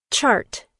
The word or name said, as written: chart